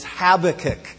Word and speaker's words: Habakkuk: Some of you have challenged my pronunciation of his name. Some of you would prefer that he be Habakkuk. And I actually have it on good authority that the correct pronunciation is Habakkuk. So everybody say that. Habakkuk.